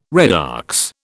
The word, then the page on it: redox: British English